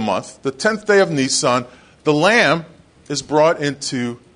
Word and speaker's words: Nisan: Nisan is spelled like the car with one less S. N-I-S-A-N, Nisan.